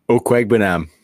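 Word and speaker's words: Okwuegbunam: Do you know how to pronounce his last name? – Okwegbenam. – There you go.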